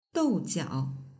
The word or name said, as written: doujiao